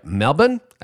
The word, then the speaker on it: Melbourne: actually the largest Baptist church in Australia in Melbourne, or I'm going to try to say it right, Melbourne, as my friends would say in Australia.